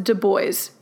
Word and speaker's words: Du Bois: And yes, he wanted it pronounced Du Bois in the English fashion, he told us.